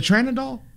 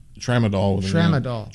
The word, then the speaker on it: Tramadol: Am I saying it wrong? Is it a tranadol? – Tramadol.